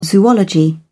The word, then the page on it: zoology: British English